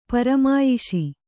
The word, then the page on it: ಪರಮಾಯಿಷಿ: paramāyiṣi